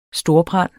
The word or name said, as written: STORBRAND